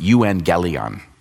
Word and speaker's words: euangelion: Gospel, our word gospel, actually comes from a direct translation of a Greek word that's pronounced euangelion.